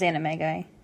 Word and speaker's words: animagi: And we learn that they all became animaguses, which I believe is the right pronunciation. It's not animagi. – It is animagi. – It is animagi? – Mm-hmm. – All right, good, because that makes so much more sense.